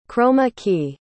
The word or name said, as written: chroma key